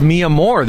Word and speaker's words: Mi Amor: That's pronounced Mi Amor, this food truck here. – Yeah, my love in Spanish.